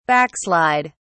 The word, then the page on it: backslid: British English